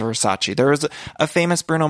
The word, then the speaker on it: Versace: And I just want to let everyone know, of course, I know that it's Versace. I was using the Showgirls pronunciation.